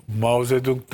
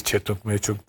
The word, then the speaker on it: Mao Zedong: Hasbelkader Mao Zedong döneminde, bak onu en iyi telaffuz edenlerden biriyimdir Türkiye'de. Herkes Çetong Meçong der. Çinlilerden duymuştum. Çünkü Mao Zedong beyefendinin adı.